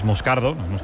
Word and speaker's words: Moscardo: Como bien ha pronunciado Unai, es Moscardo, brasileño.